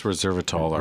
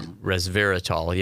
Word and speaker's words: resveratrol: And the the mechanism or the what, what they think is the um, ingredient that helps that is res, resveratrol. I, I, don't know if I'm pronouncing. – Yeah, I right. think it's resveratrol. Res, resveratrol, yeah,